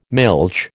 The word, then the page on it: milch: American English